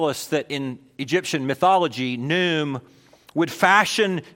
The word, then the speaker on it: Khnum: Until you understand the context of Egyptian worship, last week I introduced you to a god of the Nile who is called Nu or Num. Sometimes you see him spelled like K-H-N-U-M.